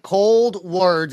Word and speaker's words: Coldwards: And then recently you left Saliva, and you have your new band, Coldward, is how it's pronounced. And to me, it looks like Coldward. It's Cold, W-A-R-D-S. I don't know how someone could pronounce that differently, but when we started the show, you made sure I said it right.